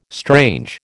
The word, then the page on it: strange: American English